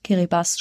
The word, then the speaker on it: Kiribati: Also da ähm, in dem äh, Raum haben wir, äh, sind wir auf viele Atolle getroffen und vor allen Dingen auch in Kiribati schreibt man's, Kiribati spricht man das.